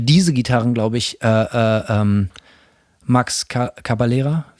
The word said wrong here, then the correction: Cavalera: wie spricht man den Namen aus? – Cavalera.